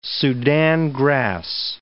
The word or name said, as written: Sudan grass